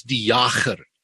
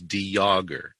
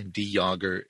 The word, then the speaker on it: de Jager: The pronunciation that we use is De Jager. It's a very silent J. De Jager. It's a French pronunciation, which fits in with the distant ancestry of ours.